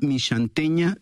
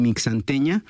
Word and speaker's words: Mixanteña: Esta canción la hicimos en colaboración con una banda que en realidad es nuestra banda mexicana favorita y se llaman la banda Mixanteña de Santa Cecilia. Se escribe con X, se podría leer también Mixanteña,